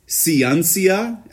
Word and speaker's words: Xianxia: Xianxia. It's spelled X-I-A-N-X-I-A.